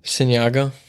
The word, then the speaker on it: Signaigo: Signaigo.